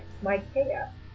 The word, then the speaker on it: Micaiah: One of the lesser known is a prophet by the name of Micaiah, and we assume that's how you pronounce it because it's spelled similar to Isaiah.